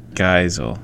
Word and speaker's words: Geisel: Yeah, Ted Geisel is his real name.